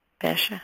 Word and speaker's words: fascia: Fascia.